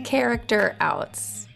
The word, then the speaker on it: Character Outs: And again, it's called Characters Out Podcast. Am I saying that right? – Character Outs. – Character Outs. – Character I switched the outs.